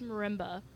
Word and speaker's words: marimba: Is that, did I say that right? – It's marimba. – Marimba. – Marimba. M-A-R-I-M-B-A.